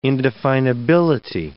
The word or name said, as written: indefinability